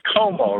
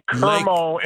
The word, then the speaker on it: Como: You know, the place you're moving to is Como, right? So it rhymes with Perdomo. You don't say Kermo and Perdomo.